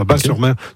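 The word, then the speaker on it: Batz-sur-Mer: Et surtout, la crêperie s'appelle le Dervin à Bas-sur-Mer, qui font les meilleures crêpes de la oui, Bat, moi, on prononce Bas, le T ne se prononce pas, Bas-sur-Mer.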